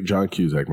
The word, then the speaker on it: John Cusack: John Cusack really is terrible. Amazing. Mm-hmm. – I can't stand John Cusack. Wait, why? – I don't like him. – What is your favorite John Cusack movie? – Hot Tub Time Machine.